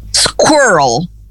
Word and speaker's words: squirrel: Squirrel, e rima com girl, tá? Então, garota e esquilo rimam perfeitamente. Squirrel e girl.